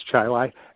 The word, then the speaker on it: Chili: It's spelt like chili, but it's pronounced Chi-Lai, and that's just south of Rochester.